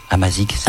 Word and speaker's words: Amazigh: Je le dis bien, Amazigh.